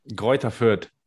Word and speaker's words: Greuther Fürth: Greuther Fürth.